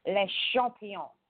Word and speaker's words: Le Champion: You're saying it wrong because he's saying it like he's a French person from Quebec. So it's Le Champion. Okay? And that's how he says it. Nobody else says it like he does.